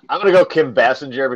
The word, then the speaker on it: Basinger: It's pronounced Basinger.